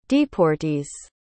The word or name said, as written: deportees